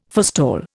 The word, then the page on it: forestall: Indian